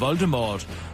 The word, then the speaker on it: Voldemort: Rowling øh, har tidligere under interviews fortalt, at den sidste stavelse i øh, Voldemort, som skal, øh, skal udtales som det franske mor, som betyder død.